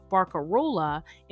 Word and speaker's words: barcarola: English speakers, largely introduced to this musical form through opera, flattened the many vowels of the Venetian word barcarola into the easier to pronounce barcarolle.